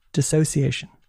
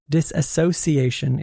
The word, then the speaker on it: dissociation: It's not dissociation. It's dissociation. Disassociation is incorrect.